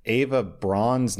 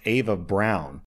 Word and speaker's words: Eva Braun: Also, OP clarifies, I actually didn't know this, but apparently in German, Eva Braun's name, I thought it was pronounced Eva Braun, is pronounced Ava Brown.